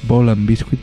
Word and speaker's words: Ball and Biscuit: Bell and Biscuit, Ball and Biscuit, perdón, no Bell, Ball, hay que pronunciarlo bien, Ball and Biscuit de White Stripes.